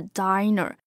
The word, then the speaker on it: diner: 大家需要稍微的注意一下它的发音哈，是 diner。